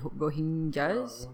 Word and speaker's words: Rohingyas: How do you pronounce it? Rohingyas? – Yeah.